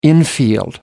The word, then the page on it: infield: American English